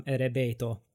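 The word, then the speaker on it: erebeto (エレベーター): So for I can give you the example of elevator. It would come erebeto.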